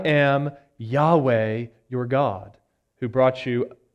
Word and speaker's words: Yahweh: That is the Tetragrammaton, and we have just put Lord in there, but probably the best way to pronounce that would be Yahweh. That's the best understanding that scholars have for how to say it.